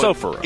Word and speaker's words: Sapporo: Sapporo. – Accent on the first syllable. – Gotcha. Okay. That's it. Yeah. – I like going Sapporo.